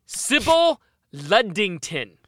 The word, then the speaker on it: Sybil Ludington: Someone who rode farther and debatably did more was going to be, Sybil Ludington. Did I pronounce that correct, Logan? – You did.